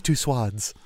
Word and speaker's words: Tussauds: It's Tussauds. JBC is pronounced Tussauds.